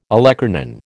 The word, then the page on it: olecranon: American English